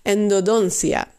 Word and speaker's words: endodoncia: Of het wordt ook genoemd eh, endodoncia endodoncia. Eh, of nee, we gaan even de klemtoon goed leggen. Endodoncia. endodoncia.